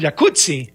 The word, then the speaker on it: jacuzzi: Entonces deberíamos pronunciar el jacuzzi. – Exacto. – Como pizza. Como rampazzo, como Claudio Rampazzo.